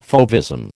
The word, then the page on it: fauvism: American English